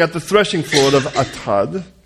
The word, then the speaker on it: Atad: Then they came to the threshing floor of Atad. Could you say Atad? Atad. No, you can't say it like Atad. Atad. Atad. That's a little better.